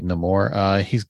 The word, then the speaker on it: Namor: Am I saying that right? Namor? Namor? Namor? – I have a Namor, Namor. – It's Roman backwards, actually. Namor.